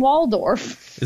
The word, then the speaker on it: Waldorf: Muppet babies should have had Statler and Wardolph as two middle-aged men who just stood around making fun of the babies. – Yeah. Oh, Statler. not to correct your pronunciation, but Statler and Waldorf.